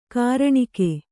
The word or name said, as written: ಘೂರ್ಣಕ